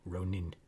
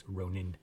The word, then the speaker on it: Ronin: So they never drag letters out unless they double it up. So that would definitely be Ronin. That'd be more close to the pronunciation. It's Ronin.